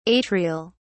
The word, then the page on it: atrial: British English